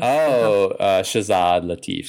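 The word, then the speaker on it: Shazad Latif: Shahzad Lateef.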